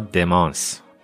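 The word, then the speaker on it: démence: جدای از بحث افسردگی، ما در علم اعصاب، مغز و اعصاب، نورولوژی یه بیماری دیگه داریم به نام دیمنشا یا دمانس با تلفظ فرانسه، یعنی زوال عقل، چیزی که آلزایمر در دل اون جا میگیره.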